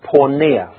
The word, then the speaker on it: porneia: The original word for marital unfaithfulness is a simple word in the Greek pronounced porneia, from which we get the word pornography.